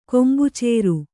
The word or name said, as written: ಕೊಂಬುಚೇರು